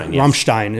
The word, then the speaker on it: Rammstein: Rammstein is how you pronounce it.